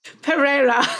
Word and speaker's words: Pereira: You know the guy, Mito Pereira. Yeah. – No, that's not it. – Pereira.